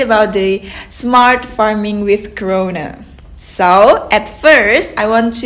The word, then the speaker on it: Krone: The German pronunciation would be Krone. – Krone. – But of course, due to the fact that we are selling in more than 60 countries, many of them are speaking English. If they do the communication and with us, and therefore the English pronunciation Krone is also completely fine.